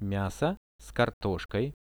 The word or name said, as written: miaso s kartoshkoi